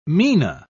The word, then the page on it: mina: British English